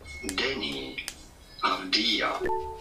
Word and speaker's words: Avdija: Deni Avdija. Yeah. Avdija.